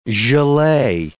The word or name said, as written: gelee